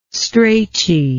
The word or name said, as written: strachey